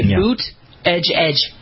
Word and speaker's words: Buttigieg: He would say Boot Edge Edge.